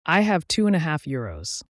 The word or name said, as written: euros